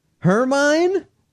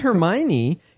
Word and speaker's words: Hermine: Even Florida hunkers down for Hurricane Hermine. Is that how you pronounce it? It's not Hermione. It's H-E-R-M-I-N-E. Hermine. – Hermione. That is from... – No. I-O-N-E is Hermione.